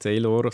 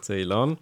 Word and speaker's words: Ceylan: Ceylan, also nicht Ceylor, sondern Blauband, sondern Ceylan.